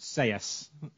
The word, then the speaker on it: Sellés: I know I pronounced it Ruben Selles, and I know it's actually pronounced Seyes, but I'm...